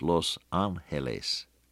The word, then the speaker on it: Los Ángeles: Well, If you give it the right Spanish pronunciation, it's Los Ángeles.